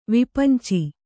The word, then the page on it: ವಿಪಂಚಿ: kannada; vipanci